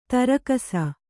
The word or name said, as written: ದೊರಕಿಸು